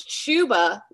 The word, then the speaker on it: Chuba: It's Chuba, like Chuba. Shoes. – Okay. – Rhymes with Tuba.